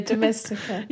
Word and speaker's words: Domestika: You can also watch some YouTube videos, like, and follow someone on Patreon, or you can also do Skillshare courses or Domestika. I don't know if I say it right. – Oh, yeah, Domestika.